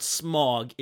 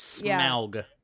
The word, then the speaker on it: Smaug: Yes, it's not smog, it is Smaug.